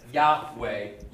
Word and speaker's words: Yahweh: Another side note, sorry, but the Jehovah is the same name. – Right. – It's a different way of people pronouncing it. So instead of Yahweh, you get Yahovah. It's different vowels that have been put in there. And, you know, a J instead of a Y.